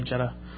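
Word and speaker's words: gEDA: And it's pronounced GDA or Jetta. – Yeah. – Jetta. – Mostly depending on which side of the pond you come from, it sounds like.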